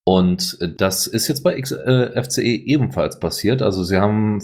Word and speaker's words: XFCE: Und da kommen wir sofort zu den Desktop-Interfaces, nämlich X-Face, also X-Face wird es nicht ausgesprochen, sondern XFCE 4.20 ist veröffentlicht worden mit einigen sehr sinnvollen Neuerungen, nämlich unter anderem experimenteller Unterstützung für den Wayland-Anzeigeserver.